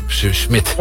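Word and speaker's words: Smith: Het is wel op zijn Nederlands uitgesproken, ja. – Smit. – Ja. Maar wat doet die H dan? Die doet dus eigenlijk niks.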